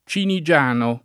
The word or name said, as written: Cinigiano